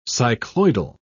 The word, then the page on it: cycloidal: English; British English